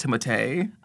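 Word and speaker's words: Timothée: Also, I saw that it's pronounced Timotei.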